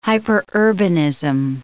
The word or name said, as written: hyperurbanism